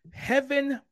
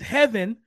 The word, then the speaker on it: Hevin: On the prowl, ladies and gentlemen, Heaven Brown, I think that's how you pronounce it. Heaven, H-E-V-I-N, instead of like you think about Devin, D-E-V-I-N.